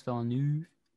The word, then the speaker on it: Villeneuve: Yeah, it's Villeneuve.